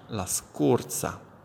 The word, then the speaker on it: scorsa: Nella pronuncia, mi raccomando, la O chiusa, la scorsa. Altrimenti sembrerà stiate parlando della scorza, la O è aperta, in questo caso la scorza, cioè la buccia del limone, ad esempio, o il guscio di alcuni frutti, la scorza, che viene chiamato anche così, scorza. Si scrive però con la Z e non con la S, oltre al problema della O aperta.